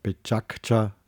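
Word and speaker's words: PechaKucha: Das kommt aus dem japanischen Bechakucha, wird es ausgesprochen, ähm, und bedeutet so viel wie Stimmengewirr, Geplauder, und darum geht es.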